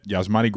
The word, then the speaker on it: Yasmani: Uh, it, was, it, was, tweeted that Yasmani Grandal was out there uh, jogging. I believe it's a soft J uh, with the training staff on the field before the game.